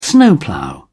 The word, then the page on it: snowplough: British English